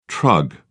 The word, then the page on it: trug: British English